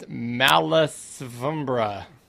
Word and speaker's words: Malasombra: It's Malasombra. – Malasombra. Malasombra. – They put a hat on the O, so I thought it was a V.